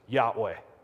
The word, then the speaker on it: Yahweh: In Hebrew, it's four consonants, YHWH, commonly pronounced Yahweh.